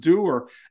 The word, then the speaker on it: dour: I completely understand what dour means until somebody told me that it's pronounced doer.